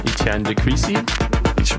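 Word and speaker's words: Étienne de Crécy: Etienne de Klerk coming up, right? – Oh, yeah. Forgot about that one. December 13th, Etienne de Crécy. – We'll go with that. – I butchered it.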